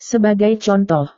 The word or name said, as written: sebagai contoh